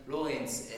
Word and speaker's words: Laurens: Mas falando pelo francês mesmo, o meu nome se pronuncia de Lawrence, L-A-U-R-E-E-N-S.